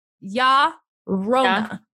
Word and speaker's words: La Llorona: So you pronounce it with a ya. – Yeah. – Instead of a la. Okay. So, hey, have you ever heard two white bitches trying to speak two white bitches from Florida trying to pronunciate is not a word. Enunciate. So La Llorona is pronounced like ya rona.